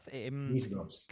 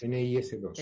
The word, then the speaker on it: NIS2: Directiva NIS2, creo que lo he dicho bien, ¿verdad? – NIS2, ¿qué es? NIS2. – Eso es.